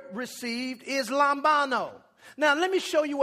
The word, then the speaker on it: lambano: That word received there in that verse is the word lambano. It's spelled L-A-M-B-A-N-O.